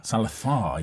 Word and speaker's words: Salazar: Salazar is definitely how he says it.